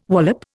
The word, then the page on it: wallop: Indian